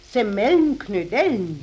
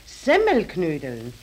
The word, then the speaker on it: Semmelknödeln: Jetzt hast du nämlich gerade gesagt Semmelnknödeln. Ist recht, Semmelnknödeln. Nein, nein. Oh, ja. okay, richtig muss es eigentlich heißen Semmelknödeln. Die Semmel muss man dabei betonen, weil doch die Knödel aus Semmeln ja, gemacht ja.